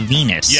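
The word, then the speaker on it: Venis: Or it rhymes with penis. – Yes, like, you get it. – But you don't say that. You only say Venus.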